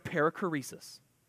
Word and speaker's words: perichoresis: That is a Greek word, and the word is pronounced perichoresis. Raise your hand if you've ever heard that word before.